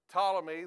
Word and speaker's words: Ptolemy: Ptolemy, the P is silent. Ptolemy,